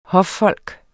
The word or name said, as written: HOFFOLK